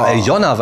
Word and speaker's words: Alena: Und ich sage Aljona, weil ich glaube, dass der Name Alena eigentlich russisch ausgesprochen wird und Aljona heißt.